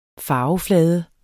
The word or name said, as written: farveflade